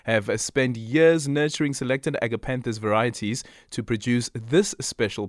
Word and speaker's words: Agapanthus: Andy, is it Agapanthus? – It's Agapanthus. – Agapanthus. Okay, perfect. Just needed to get the pronunciation right.